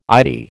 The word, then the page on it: Eyetie: American English